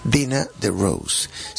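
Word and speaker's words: Dena DeRose: Dina DeRose, se escribe Dina, D-E-N-A, se escribe Dina, y el DeRose todo junto, ¿no?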